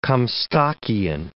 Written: Comstockian

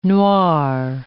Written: noir

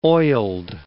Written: oiled

